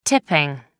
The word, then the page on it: tipping: American English